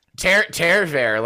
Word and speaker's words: Tarver: Tarver, like Tare Bear.